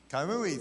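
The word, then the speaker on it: Coamo: And a few of us decided to go to this hot springs up in Caimo, Puerto Rico. I might have mispronounced it. It was like half an hour. But a few of us said, huh? Kaimui, thank you.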